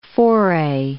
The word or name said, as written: foray